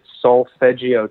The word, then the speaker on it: solfeggio: Solfeggio tones. It's spelled S-O-L-F-E-G-G-I-O.